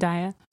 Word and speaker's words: Zendaya: Zendaya, is that how you say her name? – Zendaya.